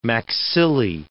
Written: maxillae

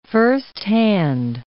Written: firsthand

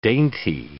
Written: dainty

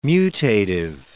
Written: mutative

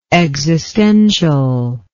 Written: existentially

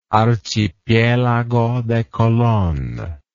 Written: archipielago de colon

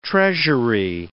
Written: Treasury

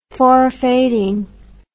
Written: forfaiting

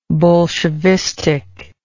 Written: bolshevistic

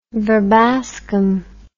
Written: verbascum

